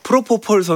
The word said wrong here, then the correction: 프로풋볼: 선수가 아니라 죄송합니다. 지금 많이들 오해를 하시는데 제가 그 발음이 꼬여서 프로풋볼 선수입니다. 프로포폴 선수 아닙니다.